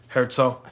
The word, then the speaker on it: Herzo: It's Erzgenaurach, which it's usually shortened to just Erzo. Herzo. – Herzo. – H-E-R-Z-O. No, don't say it like an Italian.